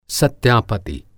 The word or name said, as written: सत्यापति